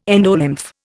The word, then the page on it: endolymph: Indian